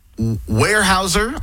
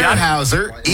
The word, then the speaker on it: Weyerhaeuser: Weyerhaeuser, I believe I'm pronouncing that correctly. – Yep, Weyerhaeuser, Eastgate Tire, and Massey Supermarket.